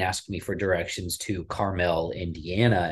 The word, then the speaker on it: Carmel: So there's a Carmel, California, and there's also a town called Carmel, Indiana, and believe me, it is pronounced Carmel.